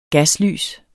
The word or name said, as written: GASLYS